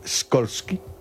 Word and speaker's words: Skolsky: ¿Cómo se pronunciaría en polaco Skolsky? – Skolsky. Como tú lo has dicho.